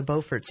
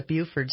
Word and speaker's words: Beaufort: Everyone's heard of the Beaufort scale. Some people call it the Buford scale, but that's an incorrect pronunciation.